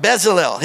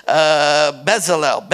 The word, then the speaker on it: Bezalel: All right, Bezalel, his name, it's from three Hebrew syllables. It's B, Zal, and El. El is the name of God, one of the holiest revered names of God. But his name is Bezalel.